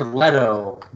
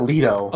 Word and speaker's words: Leto: And his name is Jared Leto, by the way. Not Leto.